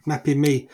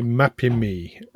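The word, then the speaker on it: Mapimí: Mapimi. In Spanish, okay. The emphasis is always on that little accent or on the penultimate syllable. – Right, okay, Mapimi.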